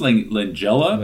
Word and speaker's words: Langella: And even before we see Sheen or Langella, if I'm saying that correctly. – Yeah. – Okay. – Good. I think it's Langella, but either way, I think, yeah.